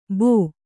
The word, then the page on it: ಭೋ: bhō